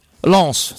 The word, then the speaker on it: Lens: vi è andato lo ricorderai, è stato pagato da Lens, ho detto bene Lens. – Lens, si sente la S finale.